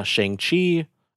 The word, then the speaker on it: Shang-Chi: So the premise, so for background, what inspired me to want to talk about this is I saw Shang-Chi is how you pronounce it.